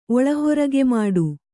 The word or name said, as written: ಒಳಹೊರಗೆಮಾಡು